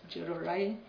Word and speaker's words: Njororai: He was asking me how to pronounce the second name, and I told him we don't pronounce the N, so it's Njororai. That's how we do it in our cultural language.